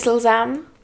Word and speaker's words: slzám: Everyone, listen up, this is how you pronounce it. Kapky, kapky, wait. – Kapky. – Kapky. – Proti. – Proti. – Yeah, but you're not a cat. – Proti. – Proti. – Proti. – Slzám. – Slzám.